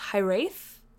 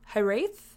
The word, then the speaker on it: hiraeth: I think it's pronounced Hirath. Hirath? It's spelled H-I-R-A-E-T-H.